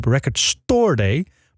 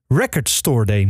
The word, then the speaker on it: Record Store Day: De klemtoon ligt bij jullie op Record Store Day. Maar het zou in my opinion Record Store Day moeten zijn.